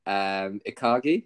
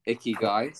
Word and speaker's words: ikigai: Ikagi, is that how you say it? – It's Ikigai. – Ikigai, so yeah. Got that totally wrong.